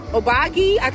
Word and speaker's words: Obagi: Then we got this donation from Obagi, that's how you say it, medical.